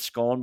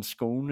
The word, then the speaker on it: scone: When does Scone become Scone in what regions? I don't know what it is for our English listeners. I think for our Irish listeners down south, it's Scone. My pronunciation of it up north was Scone, but obviously it didn't follow me down to Dublin because being called Scone would be ridiculous, but Scone's fine.